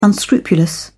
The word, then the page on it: unscrupulous: British English